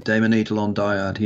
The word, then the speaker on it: Daemon Eidolon Dyad: The Daemon Eidolon Dyad, yeah. – Did I say that right? – Yeah, Daemon Eidolon. They're Greek terms.